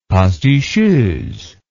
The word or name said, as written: pasticheuse